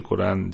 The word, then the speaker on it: Corán: En el acento punjabi, el qaf no se pronuncia como debe ser pronunciado, el qaf gutural. Y al decir la palabra Corán, no la sacan de la garganta, sino que parece un kaf normal.